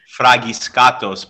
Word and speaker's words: Fragiskatos: Well, I know it's pronounced in Greek. It's Fragiskatos, but it just sounds obnoxious to do the enunciation.